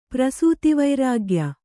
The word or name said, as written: ಪ್ರಸೂತಿವೈರಾಗ್ಯ